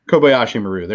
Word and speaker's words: Kobayashi Maru: So the thing that bothers me the most about the I always say the fucking name wrong. Kobayashi Maru. – Kobayashi Maru. – Kobayashi Maru. There you go.